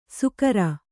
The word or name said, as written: ಸುಕರ